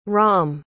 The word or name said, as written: raam